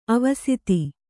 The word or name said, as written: ಆವಸಿತ